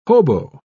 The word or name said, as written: hobo